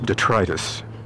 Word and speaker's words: detritus: Which apparently is really supposed to be pronounced detritus, which just sounds wrong to me. I'm sorry, but it does. Probably because for my entire life, I'd never heard it pronounced until somebody wrote to me and said, you know, and I looked it up and sure enough, it's detritus.